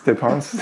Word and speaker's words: dépenses: There was one article in particular that really struck me, which was called In Defense of Our Dépenses. I don't know if I'm pronouncing that word right. – Dépenses. It's a French word.